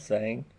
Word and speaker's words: Zhang: Is that how you say it? Zeta Zhang? The H? – Zhang.